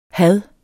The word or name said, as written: had